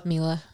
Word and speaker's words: Mila: I say Mila. Is it Mila? – Mila. – I've said it right the whole time.